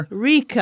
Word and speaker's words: paprika: Paprika. – Like, paprika. Paprika. – Paprika. – Chicken paprika. – So the accent is on the P. – I think the emphasis is on the first syllable, I believe is the case.